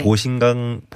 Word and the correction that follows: 보신각종: 보신각종 타종 행사.